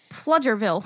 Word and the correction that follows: Pflugerville: And here is the correct pronunciation of the five. So one is Green, two is Bernie, three is Nacogdoches, four is Waxahachie, and five is Pflugerville.